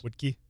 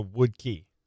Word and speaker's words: Woodke: W-O-O-E-D dash key. – So wood key. – Was that woo-ed? Woo-ed? – No, it's wood. – W-O-O-D.